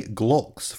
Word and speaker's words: Glulx: Newly created projects are set up with the GLOLKS format. GLOLKS, and I might not be pronouncing that correctly, is spelled G-L-U-L-X.